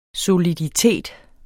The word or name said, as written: soliditet